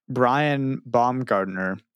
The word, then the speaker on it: Brian Baumgartner: Brian Baumgartner.